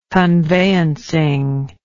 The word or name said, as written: conveyancing